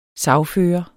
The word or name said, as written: sagfører